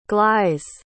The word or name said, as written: glais